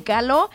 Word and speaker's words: Caló: Vamos ahora con Caló, con acento en la O.